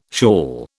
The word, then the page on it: shawl: British English